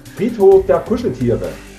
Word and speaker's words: Friedhof der Kuscheltiere: Friedhof der Kuscheltiere nennt sich das. – Oh, that's how you pronounce it in Germany? – Yeah. Friedhof der Kuscheltiere.